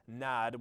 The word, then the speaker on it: nad: That word for nod is related to the word for nad, which with a long A, and it means wandering.